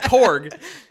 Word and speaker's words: Korg: Because, like, I like the way the movie starts with Korg kind of doing this narration. – Oh, yeah, yeah. – Like, sure. – He's talking to kids. – I thought you were mispronouncing Gore's name. – No, Korg.